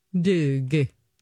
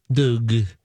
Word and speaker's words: Dogue: It's called Dog. But it's as if a snooty French person said it because it's spelled D-O-G-U-E. – Doug. – Doug.